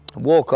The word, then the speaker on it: walk: Quindi anche se i tuoi sogni hai buttati per aria e è soffiata da tutte le parti, immagina questa foglia nel vento, walk on, walk on, non si pronuncia L in walk, eh? molto importante,